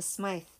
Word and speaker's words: Smythe: There's an E on the end, so I'm thinking it's Smythe, not Smith.